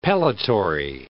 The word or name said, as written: pellitory